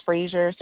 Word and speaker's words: Frasier: Last name is Frazier, spelled with a S, Frasier.